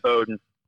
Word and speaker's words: Bowdoin: Hamilton, Williams, Middlebury, Trinity, Bates, Tufts, and I might be saying that word wrong. Bowdoin? Is that how you say it? – It's Bowdoin, yeah, Bowdoin.